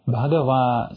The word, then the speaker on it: Bhagava: Bhagava is, of course, the Buddha, the Blessed One. Now, just as a kind of a peculiarity of this phrase, notice that it ends with a long A, okay? Bhagava savatthiyang viharati jetavane anatapindikasa arame.